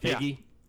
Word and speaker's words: Feige: The G I thought the G was silent. – It's a hard G. – Is it a hard... Feige? – Yeah.